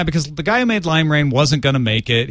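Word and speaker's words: limera1n: Lime Rain. – Lime Rain, even though it's with a one.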